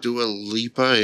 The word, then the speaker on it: Dua Lipa: Dua Lipa, yeah.